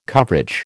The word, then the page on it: coverage: American English